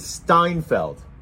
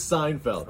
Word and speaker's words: Seinfeld: She also says Steinfeld. When she says Seinfeld. – Okay. – Steinfeld. – Yeah, that's just pronounced wrong.